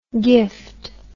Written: giffed

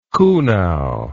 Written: kuhnau

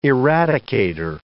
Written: eradicator

